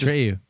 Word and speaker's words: Atreyu: And it's Atreyu.